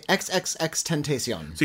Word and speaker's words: XXXTentacion: Look at Me, XXXTentacion. – So you say each X.